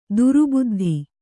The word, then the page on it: ದುರುಬುದ್ಧಿ: durubuddhi; Kannada